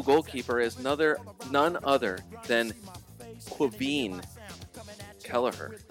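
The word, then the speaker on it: Caoimhín: C-A-O-I-M-H-I-N is pronounced Quaveen. – That is exactly right. And you put the stress on the end syllable.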